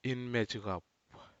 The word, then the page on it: inmejihap: Aneityum